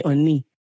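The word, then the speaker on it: Onni (언니): So since it's female to female, you can call me Onni. And it's spelled E-O-N-N-I, Onni. That's the romanization. Or you can do U-N-N-I. And then Komawoyo is G-O-M-A-W-O-Y-O, Komawoyo.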